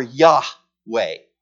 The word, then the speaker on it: Yahweh: There are two ways in English to pronounce it. One is Jehovah. If you've ever heard a song that talks about Jehovah, it's referring to that name. You'll see it a couple times in this passage. Here it's again, capital A, L, capital O, capital R, capital D. It's either Jehovah or Yahweh. Those are the two ways that you pronounce this name.